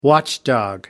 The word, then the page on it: watchdog: American English